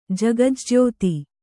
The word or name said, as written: ಜಗಜ್ಜ್ಯೋತಿ